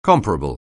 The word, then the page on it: comparable: anglais